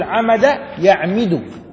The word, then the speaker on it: عمد: قال: بوزن ضرب. ضرب كيف ننطقها؟ ضرب يضري، يضري يضرب، صح؟ ضرب يضرب، ممتاز. إذن نقول عمد يعمد، ها؟ ضرب يضرب يعني عمد يعمد، تمام؟ إذن ينطق عمد يعمد، المضارع يعمد، تمام؟